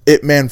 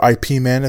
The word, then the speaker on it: Ip Man: It Man 4 is also coming out on Christmas Day, or IP Man, depending on how you want to pronounce it.